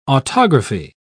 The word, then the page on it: autography: British English